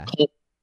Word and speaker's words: Kolb: This is Kevin Cobb spelled like K-O-L-B. – K-O-L-B, yeah. – Cole. Okay.